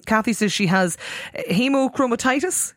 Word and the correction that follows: hemochromatosis: Can she? Hemochromatosis.